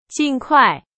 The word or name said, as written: 尽快